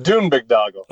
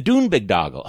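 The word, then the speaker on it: Boondoggle: This, I want to talk about how do you pronounce the headline of your piece? The Dune. You try it first. – The Dune Big Doggle. – Okay, the Dune Big Doggle. Okay, that's great. That was good.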